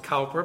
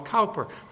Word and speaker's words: Cowper: We pronounce it Cowper, but he is British. The British call him Cowper.